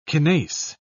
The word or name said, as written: kinase